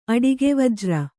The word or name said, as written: ಅಡಿಗೆವಜ್ರ